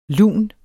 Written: lun

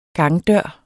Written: gangdør

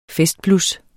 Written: festblus

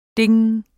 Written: ding

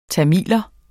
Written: tamil

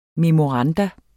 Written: memorandum

